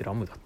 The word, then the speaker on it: 乱数: で雨村ラムダ乱数って書いてラムダって読むんだね